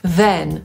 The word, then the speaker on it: then: Oba zaczynamy tak samo, czyli przez dźwięk w, język między zęby. I potem słowo pisane przez e czytamy też przez e, a więc mówimy then.